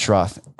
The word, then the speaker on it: Schroth: Yes, so it's Schroth. And I hear it all ways, shapes, and forms, but it's pronounced Schroth.